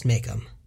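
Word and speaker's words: Majcomb: It's M-A-J-C-O-M-B, which I think is pronounced Makeham.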